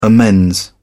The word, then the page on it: amends: British English